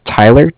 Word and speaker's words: Tyler: My name is Tyler, T-Y-L-E-R. – Okay. – And I am calling from Bank of Cardiff. It's spelled C-A-R-D-I-F-F, as in Frank Frank.